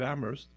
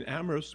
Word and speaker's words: Amherst: If in Amherst, where the H is silent,